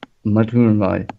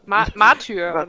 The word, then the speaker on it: Martyr: Und unter anderem ist da auch Warhammer 40k Matter. – Was? – Ich weiß nicht, wie es ausgesprochen wird. – Martyr.